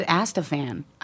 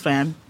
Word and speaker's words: Atzfan: I thought it was Astafan. I think I've been saying it wrong this whole time. – It's Atzfan. It sounded like you said Astafan.